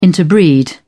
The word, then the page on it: interbreed: British English